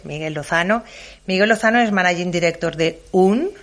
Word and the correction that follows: Hyun: lo pronuncio bien, UN. – Podríamos decir Hyun. – Hyun, vale, Hyun.